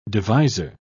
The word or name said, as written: devisor